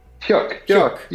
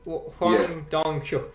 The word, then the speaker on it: Hwang Dong-hyuk: It is the brainchild of, and you're going to have to correct my pronunciation, Hwang Dong-hyuk? Hyuk? – Hyuk, you're good. – Hwang Dong-hyuk, who is a South Korean director, and this has exploded in terms of popularity.